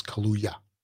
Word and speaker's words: Kaluuya: Is that how you say it? Or Kaluuya. Not like the beverage. Kaluuya? Yeah, it's Kaluuya.